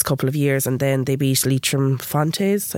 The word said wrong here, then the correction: Fontenoys: I think I'm saying that right, Leitrim Fontenoys. – Fontenoys.